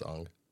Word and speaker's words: Aang: It's pronounced Aang.